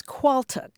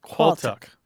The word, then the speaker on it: quaaltagh: Yes, and that word is qualtuk. Now, let me explain. It's spelled a little bit strangely. It's Q-U-A-A-L-T-A-G-H. – Qualtuk. – Yes. – Okay. – And the reason it looks weird is because it's Manx.